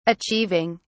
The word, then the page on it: achieving: British English